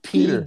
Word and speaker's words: Peadar: P E A D A R. – Oh, it's Peter. Peter. – Yeah. Peter. Yeah.